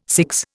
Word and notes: CIGS: Indian

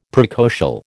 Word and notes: precocial: American English

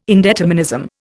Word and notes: indeterminism: Indian

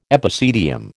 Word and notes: epicedium: American English

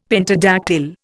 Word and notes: pentadactyl: Indian

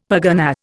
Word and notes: pergunnah: Indian

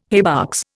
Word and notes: haybox: Indian

